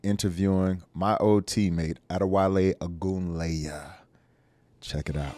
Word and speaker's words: Ogunleye: Played in the league for 11 years, undrafted, came through the ranks, powerful guy, strong guy, none other than Adewale Ogunleye.